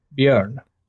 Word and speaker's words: Bjorn: Well, you have been saying it wrong, but from an American point of view, you've been saying it right. So, well, what's the right way to say it? – Bjorn. – Bjorn? So, like, I'm messing up the O, not the J.